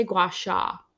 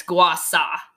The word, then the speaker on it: gua sha: just for those of you who are not familiar with a lot of this, so I don't know how you say it. I say gua sha. I think it's gua sa.